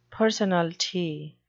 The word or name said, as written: personalty